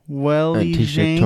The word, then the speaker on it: Weili Zhang: Weili Zhang. Torres. – Yeah, Weili Zhang. – Weili Zhang, yeah.